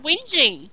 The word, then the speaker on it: Whinging: Little whining, that's how I pronounce it too, yeah. It wasn't until I heard it on something that I realized it was whinging, yeah. – Yeah, I never knew you actually said the G.